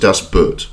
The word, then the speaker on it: Das Boot: Pronounce it Das Boot, with a slight German accent to add authority.